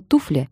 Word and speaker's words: туфля: Слово «туфля» я скажу с ударением на «ту».